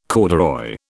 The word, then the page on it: corduroy: British English